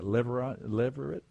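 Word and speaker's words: levirate: What does that mean to be a kinsman redeemer and this idea of a deliverate, I don't know how to even say it. Is that, how do you say that? Leverate marriage? – That's good. – What is it? Yeah, that's good. I can get by with that.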